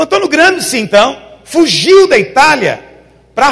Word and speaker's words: Gramsci: E o principal personagem que realmente diagnosticou esse problema foi um italiano chamado Antonio Gramsci. A pronúncia em italiano é Gramsci, mas vou falar Gramsci porque é em português.